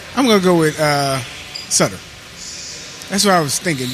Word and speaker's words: Sutter: Doug Evans, Cliff Ronning, or Brian Suter? – Sutter. – Sutter. Damn, I said it right the first time. – Is it Sutter? Sutter. – Yeah, it's Sutter.